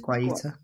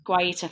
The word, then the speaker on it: Guaita: Guaita? I'm so sorry for... – I think it is Guaita. – Guaita, thank you so much.